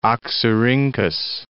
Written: Oxyrhynchus

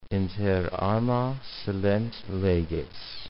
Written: inter arma silent leges

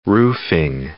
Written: roofing

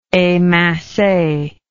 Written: emince

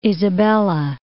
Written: Isabela Island

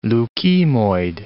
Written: leukemoid

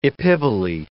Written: epiboly

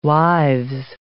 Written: wives

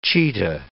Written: cheetah

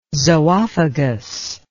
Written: zoophagous

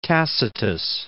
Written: Tacitus

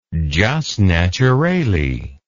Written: jus naturale

